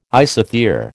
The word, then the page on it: isothere: American English